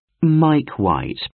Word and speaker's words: Mike White: Mike White. – How do you spell that, sir? – M-I-K-E, Mike. W-H-I-T-E, White. – M-I-K-E, Mike.